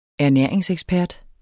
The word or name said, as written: ernæringsekspert